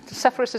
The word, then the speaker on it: Sepphoris: Sorry, Sepphoris is the English pronunciation.